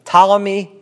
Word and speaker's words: Ptolemy: Ptolemy, you don't pronounce the P. Ptolemy got Egypt.